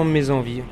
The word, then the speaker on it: Mets Envies: Si on dit Mes Envie ou Mes Envie. On dit tout simplement Mais Envie. Oui, mais c'est par rapport à l'orthographe, parce que Mais c'est M-E-T-S et Mais c'est M-E-S.